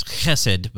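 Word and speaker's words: chesed (חסד): If you want to say it right, it's chesed, but you know, for the sake of the microphones, we're going to just keep it H.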